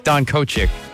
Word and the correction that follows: Donkachek: It's Donkachek. – Donkachek, yeah, Donkachek.